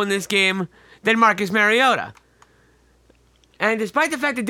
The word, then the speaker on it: Mariota: They were up 21-3 at halftime, blew the lead, fell 22-21 to the Titans on a self-touchdown pass by Mariota. You heard me say that correctly.